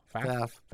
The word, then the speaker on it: Pfaff: P-F-A-F-F. Pfaff? I don't know. Pfaff? – Yeah. It'd be Pfaff. – Probably the P is silent.